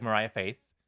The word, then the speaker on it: Moriah Faith: So it's like Mariah Faith. That's how you pronounce it.